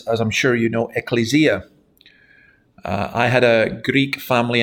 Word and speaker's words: ecclesia: She would shout, no, no, no, no, it's ecclesia. Every time I pronounced the Greek word, she shouted out. It was great fun. So it's ecclesia.